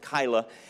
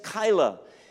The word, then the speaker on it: Keilah: And he hears that the Philistines are threatening the people of a particular city named Keilah. K-E-I-L-A-H. Maybe it's pronounced another way, but I'm going to pronounce it Keilah.